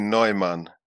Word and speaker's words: Neumann: N-E-U-M-A-N-N. German. Meaning new man, settler. – That would be Neumann. N-E-U.